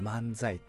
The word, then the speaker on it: 万歳: でこれってまあどういう意味かというと、その漫才ってこう万歳って書くんです、漢字で、万歳の万歳って書くんです。 1 万 2 万の万って書いて、年齢ですよ、何歳の歳っていうのを書いて万歳って書くんですけど、読み方はね、漫才って読むんですよ。